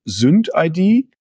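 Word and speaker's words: SynthID: Synth-ID? – Ja, es ist Synth, also Synthetisierung oder Syndicate quasi auf ID.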